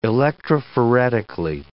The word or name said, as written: electrophoretically